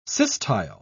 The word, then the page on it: systyle: British English